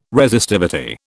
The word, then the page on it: Resistivity: British English